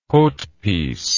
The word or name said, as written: haute piece